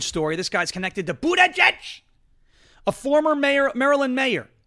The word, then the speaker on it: Buttigieg: Buttigieg! It's Buttigieg. Pete Buttigieg. You've got to say boot, then edge again.